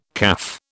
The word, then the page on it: caff: British English